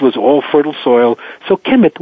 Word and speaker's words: Kemet: They didn't write vowels, so all we get down is the signs as K-M-T, Kempt. We say Kemet. I've spelled it. It's been spelled many, many different ways. As with this ancient language, spelling is not important. Pronunciation and sound is the most important. So people can spell it K-E-M-E-T, K-E-M-I-T. I spell it K-H-E-M-I-T. It's Kempt or Kemet.